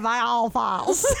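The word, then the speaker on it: Viall Files: Is it Vial files? – Yeah, because it rhymes. I think. – I've been saying Vial files.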